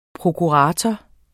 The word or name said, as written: prokurator